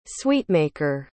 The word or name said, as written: sweetmaker